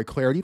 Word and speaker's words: Clardy: Follow me on Twitter at Troy Clardy. The last name is spelled C-L-A-R-D-Y.